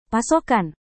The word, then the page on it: pasokan: Indonesian